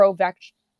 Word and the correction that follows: Ovechkin: – why did I just say his name like that? Ovechkin.